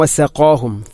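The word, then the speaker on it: വസഖാഹും (وسقاهم): വസഖാഹും വസഖാഹും, ഖാഫിനെ ഉച്ചരിക്കുമ്പോൾ വസഖാ ഖാ അല്പം കനപ്പിച്ച് ഉച്ചരിക്കണം. വസഖാഹും, അല്ലാതെ വസഖാഹും അല്ലെങ്കിലോ വസഖാഹും എന്നോ അല്ല ഉച്ചരിക്കേണ്ടത്. വസഖാഹും.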